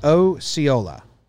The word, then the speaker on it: Osceola: Abe says it's Osceola. Osceola County. – Osceola? Yeah, I just Googled the pronunciation.